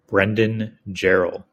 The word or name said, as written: brendan